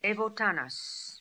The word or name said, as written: Evotanas